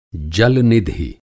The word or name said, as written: जल-निधि